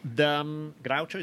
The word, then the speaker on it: Groucho: Groucho, is that how you pronounce it? – Yeah.